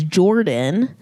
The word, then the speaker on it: Jordan: and my middle name is Jordan. And she thought it was Gordon. – Like with a soft G. – Yeah. But it's spelled with a J.